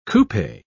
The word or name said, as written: coupe